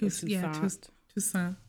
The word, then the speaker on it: Toussaint: Which I've heard these gentlemen before, again, bear with me on the pronunciation, Toussaint, I think it's Toussaint. – Toussaint.